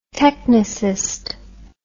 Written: technicist

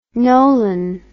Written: Nolan

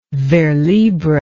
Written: vers libre

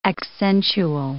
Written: accentual

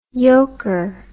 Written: yoker

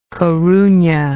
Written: coruna

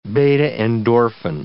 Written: beta-endorphin

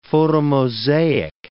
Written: photomosaic

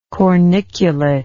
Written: corniculate

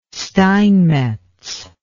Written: steinmetz

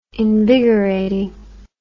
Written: invigorating

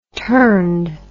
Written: turned

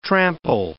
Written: trample

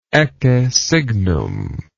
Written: ecce signum

